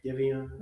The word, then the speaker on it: Giveon: Giveon.